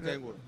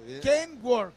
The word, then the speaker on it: Kenworth: agradezco el Kingworth, pero es Kenworth. – Kenworth es una fusión de dos apellidos. – Ok, perdón. Es Kenworth. Está bien. Kenworth.